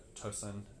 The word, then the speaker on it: Tosun: And then the other sort of two were Tosun and... Is that how you pronounce his name? – Yeah.